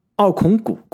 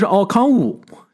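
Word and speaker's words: Okongwu: 这个奥孔古，国内非要翻成奥孔古，其实他那个 G 是不发音的，他英文的发音应该是奥康武，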